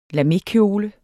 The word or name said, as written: LAMEKJOLE